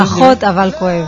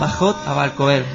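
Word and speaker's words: Pajot Avalkoev (פחות אבל כואב): Pajot Avalkoev, ¿se pronuncia? – Casi perfecto. – A ver, ¿cómo Pajot Avalkoev. – Pajot Avalkoev, muy bien.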